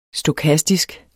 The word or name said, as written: stokastisk